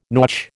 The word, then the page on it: nautch: American English